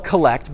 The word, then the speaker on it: collect: It's spelled collect, but it's pronounced collect. I don't know why.